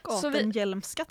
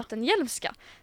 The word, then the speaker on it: Gattenheimska: Eller, ja, jag pratade med Eva där tidigare hur man ska uttala det här jobbiga namnet. Hon säger Gattenheimska, tror jag. – Gattenhjälmska, tror jag. – Gattenhjälmska. Så vi kör på hennes kort, så om det är fel så får ni gå till henne.